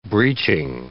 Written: breeching

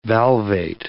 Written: valvate

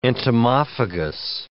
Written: entomophagous

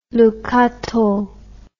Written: Le Cateau

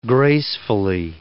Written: gracefully

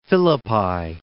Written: Caesarea Philippi

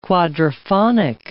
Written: quadriphonic

